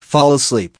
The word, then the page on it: fall asleep: American English